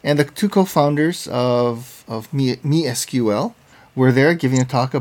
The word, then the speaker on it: MySQL: And by the way, I should note, I pronounced it incorrectly. It's not MySQL like most of it. It's actually MeSQL.